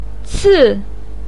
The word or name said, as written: ci4